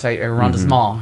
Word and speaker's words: arrondissement: I think it's arrondissement, arrondissement, however you say that. That's how you say it.